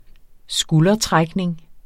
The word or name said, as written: skuldertrækning